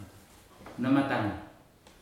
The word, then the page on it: namatagi: futuna aniwa